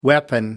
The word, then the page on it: weapon: American English